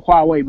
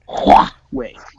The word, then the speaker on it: Huawei: That and Huawei the Huawei Mate X. – You better pronounce Huawei. – My bad. Huawei.